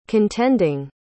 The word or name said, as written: contending